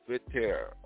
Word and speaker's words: Vautier: and that is spelled S-A-U-N-D-E-R-S, which is Saunders versus V-A-U-T-I-E-R, Vautier or Vautier, and require the trustees to transfer absolute legal title to the trust assets to the beneficiaries.